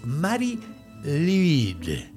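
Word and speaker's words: Mari Lwyd: Bueno, pues comencemos como cada episodio describiendo qué o quién es Mari Lid. Es una pronunciación un poco difícil porque si hay un idioma sumamente extraño que se escribe y se dice de una forma rarísima, definitivamente es el galés.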